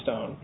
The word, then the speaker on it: ston: S-T-O-N is pronounced stone, I think that the board made a number of other objective factual determinations about the marks themselves.